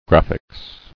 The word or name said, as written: Graphics